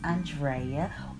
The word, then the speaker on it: Andrea: I am your vivacious host, Andrea, or Drea, if you butcher the A-N of my name.